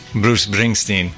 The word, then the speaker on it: Bruce Springsteen: Bruce Springsteen.